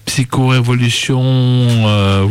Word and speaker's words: Psycho-Révolution: Voilà, c'était voilà, Psycho-Révolution, vous avez pu écouter, on prononce comme ça, non? – Ouais.